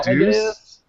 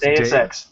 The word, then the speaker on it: Deus Ex: D-E-U-S. – No, it's deus ex. – It's dude. Deus? – Yes. – It's like, not deuce? – I thought it was dude. It's not. It's deus ex. You just said it was... been around for a while. I mean, that's how you pronounce it.